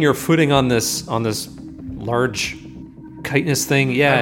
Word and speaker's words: chitinous: Did you say chitinous? – Sure did, baby.